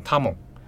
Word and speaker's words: 多聞: 好，那多闻这个名字在日文里面念，它如果直接汉语呃汉字的读音读出来的话，会变成他蒙，所以它在故事里面大部分都会叫啊他蒙他蒙，嗯，好多闻。